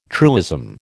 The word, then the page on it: truism: American English